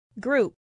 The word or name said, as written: group